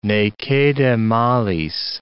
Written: ne cede malis

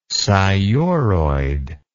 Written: sciuroid

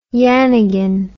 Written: yannigan

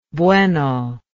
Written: bueno

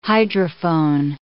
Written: hydrophone

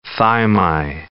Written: thymi

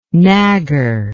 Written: nagger

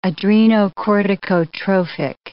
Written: adrenocorticotrophic

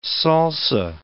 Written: salsa

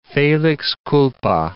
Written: felix culpa

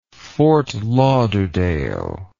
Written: fort lauderdale

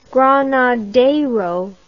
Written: granadero

